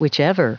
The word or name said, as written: whichever